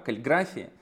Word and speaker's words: каллиграфии: Но эта красота операционной системы стала возможна, потому что когда-то в колледже Стив Джобс ходил на уроки каллиграфии, по-моему, ударение на второе А, каллиграфии.